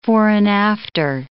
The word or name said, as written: fore-and-after